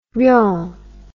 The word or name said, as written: Riom